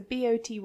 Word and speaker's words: Boty: I loved all the stuff about like Pauline Boty, if that's how you say her name. I'm not 100% sure. – Not sure. I think so. Boty.